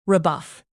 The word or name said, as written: rebuff